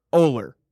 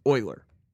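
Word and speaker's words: Ohler: So the common one, and my dad will agree because he's gotten this his whole life too. The common one is Euler.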